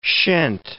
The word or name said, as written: shent